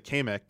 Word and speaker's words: Kamek: Uh, the pronunciation of the uh, they said Kamek. Oh yeah. I, I, was like, what? What'd you say? – Kamek? – is, is that how you say it? I was like, apparently '90s Nintendo of America, that is how you pronounce it, Kamek.